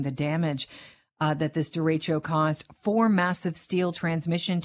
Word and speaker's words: derecho: Is it derecho? Am I pronouncing that correctly? – You're correct. It's derecho.